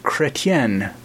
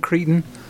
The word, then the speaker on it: Chrétien: But then she got to reading it, and it's about the former Prime Minister of Canada, Chrétien, Jean Chrétien. But that's my fault. She even said, how do you pronounce this thing? And I misled her. I thought she was saying Cretan.